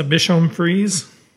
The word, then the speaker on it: Bichon Frise: Monica Newhard said that her brother was letting the family's 4 dogs, including in Bicon Freeze Zoe, out to play in a family's... – It's a Bichon Frise. I think that's how you pronounce that.